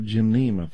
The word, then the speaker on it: Gymnema: That is spelled G-Y-M-N-E-M-A, Gymnema.